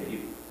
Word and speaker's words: Ohtani: While, I'm not going to say his name right, Ohtani, kind of an MLB player. – Shohei Ohtani.